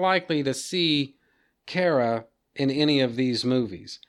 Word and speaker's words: Kara: And by the way, guys, that's how you pronounce that character's name. It rhymes with Sarah, not Mara. The character's name is Kara.